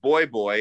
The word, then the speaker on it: voy voy: Um, so I'm, uh, out of money again and i go to this place voy voy it's spelled v-o-y v-o-y so i know it sounds like a gay club boy boy really boy boy just means I'm coming, I'm coming.